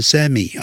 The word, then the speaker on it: semi: 我现在我算是 semi，这个这个也可以念成 semi 啊，semi retired，半退休的状态了。